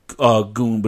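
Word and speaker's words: Suzuki-gun: It's pronounced Goon, but it's spelled Gun.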